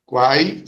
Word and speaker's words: Quae: Quai se escreve Q-U-A-E, não é isso? – É, Q-U-A-E.